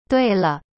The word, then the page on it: 对了: dui le